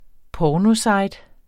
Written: pornosite

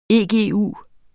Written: egu

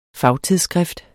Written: fagtidsskrift

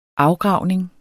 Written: afgravning

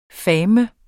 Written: fandeme

